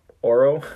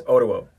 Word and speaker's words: Oruo: Yes, I will be honest with all of you listeners that I was... saying it wrong. – Yeah. – As I was preparing for this, I was calling him Oro. – Yeah. – But it's not. – I've just always known him as Oro. – It's Oruo. So remember that.